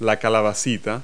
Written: zucchini

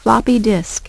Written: floppy disk

